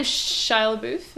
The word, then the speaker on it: Shia LaBeouf: Is his name Shia LaBeouf? Is that how you say it? – Yeah. – He's, no one knows how you say that name.